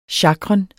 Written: sjakren

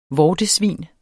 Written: vortesvin

